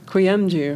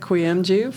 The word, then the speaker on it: Kuyumcu: Kuyumcu. – Kuyumcu. You pronounce it great. – Yeah. Thank you again, Ahmet Kuyumcu, for talking to us about using data to improve pricing decisions.